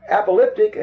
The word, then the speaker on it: apocalyptic: apolop- apocalyptic, I even lost the pronunciation of the word. Pronounce that. Right. I don't know why that lost me. Apocalyptic, apocalyptic, okay.